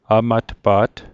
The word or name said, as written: amatpa't